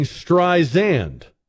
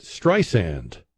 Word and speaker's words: Streisand: Siri was saying Streisand, like it had a Z in it. And Barbara says it Streisand, like sand on the beach.